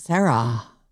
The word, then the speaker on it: Sarah: My H isn't silent. Sarah.